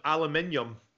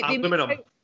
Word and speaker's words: aluminium: And aluminium. Hypertrophy. – No, Americans say aluminium. Aluminium. They say aluminum. They miss out letters.